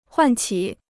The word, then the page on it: 唤起: huàn qǐ